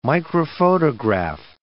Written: microphotograph

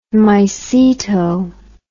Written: myceto

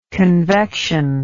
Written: convectional

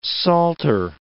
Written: salter

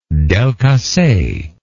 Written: Delcasse

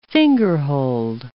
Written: fingerhold

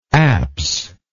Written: apsidally

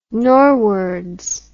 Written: norwards

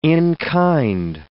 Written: in-kind

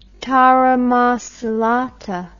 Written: taramasalata

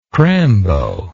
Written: crambo

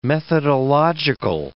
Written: methodological

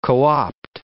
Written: co-opt

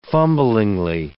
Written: fumblingly

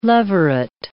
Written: leveret

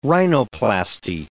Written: rhinoplasty

